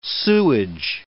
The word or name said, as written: sewage